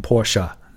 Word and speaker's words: Porsche: 我们也不能强求他叫 Porsche，所以他应该读成 Porsche，尾尾尾上还有一个 a 的音在后面。BMW 这个词就没什么好说的了。